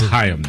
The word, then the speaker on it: Chaim: It's pronounced Chaim, though, I think. – Chaim, sorry.